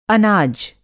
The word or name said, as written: अनाज